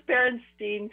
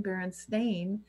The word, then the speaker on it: Berenstain: Yeah, it was Berenstein for me. – Right. Right. And now apparently you could never pronounce it that way, because apparently it's always been Berenstain.